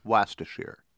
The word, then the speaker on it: Worcestershire: If you guys are right, they should change the expression to take it with a dab of Worcestershire, or as the Brits pronounce it, Worcestershire.